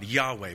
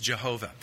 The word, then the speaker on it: Yahweh: The first word, Lord, is the tetragrammaton, the proper name of God, Yahweh. Most popular pronunciation is Jehovah.